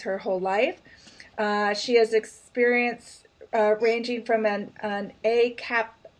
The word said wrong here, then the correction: a cappella: Is that, am I saying that right? – A cappella. – Oh, it's separated, so it's like I'm going, that doesn't sound right. Groups to gospel choirs to acoustic bands.